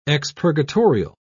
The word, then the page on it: expurgatorial: British English